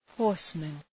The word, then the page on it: horseman: ‘hɔ:rsmən